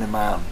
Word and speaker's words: imam: And he was what they call Ayman. I'm not sure I'm saying that right. – An imam. – Yeah, like a priest. – Imam.